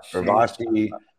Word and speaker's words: Urvashi: Urvashi. – You did great. – But thank you so much, Urvashi, for joining us.